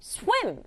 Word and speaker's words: swim: 마찬가지로 소리 나는 대로 swa 이렇게 swa, swa 쓰고 에 소리 나는 I에다가 M 붙여서 swim, swim, swim 이렇게 두 박자로 발음하지 말고 swim 한 박자로 발음하기.